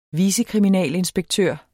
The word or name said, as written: vicekriminalinspektør